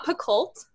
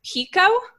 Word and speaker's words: Picoult: It's not Picoult. It's Pico. – What? – It's French. What?